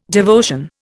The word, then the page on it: devotion: Indian